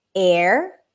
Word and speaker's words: heir: The only time that it's dropped is like in the five words, hour, heir, heir to the throne, herbs in American English, honor, and honest.